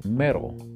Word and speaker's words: metal: Y la pronunciación sería mero, mero. Ahora, ¿por qué mero si hay una T? Dirían ustedes. Acuérdense que en inglés americano la esa T la pronunciamos como una R.